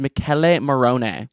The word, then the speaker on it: Michele Morone: His name is it Michael Monroe? Oh my God. Let me go look up how to pronounce this. Oh, thank God I looked it up. It's pronounced Michele Morone.